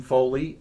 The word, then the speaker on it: Foley: Jim Foley, F-O-L-E-Y.